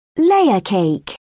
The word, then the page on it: layer cake: British English